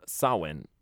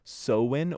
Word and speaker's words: Samhain: It's spelled Samhain, but it is pronounced as Samhain or Samhain. – Yeah. – Most pronunciations were the more A sound, so Samhain.